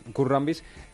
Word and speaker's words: Kurt Rambis: ¿Kurt Rambis? Es Kurt Rambis.